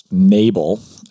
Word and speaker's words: Nabal: and there's this one guy whose name is Nabal. That's probably an English transliteration of a Hebrew word. You probably pronounce the second half of that more with more emphasis than the English.